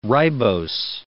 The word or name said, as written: ribose